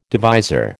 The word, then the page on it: divisor: American English